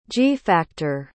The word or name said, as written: g-factor